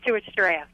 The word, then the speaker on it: Stuarts Draft: Let's talk to Stuarts Draft, Virginia. How do, how do you say that? – That's right. It's Stuart Draft.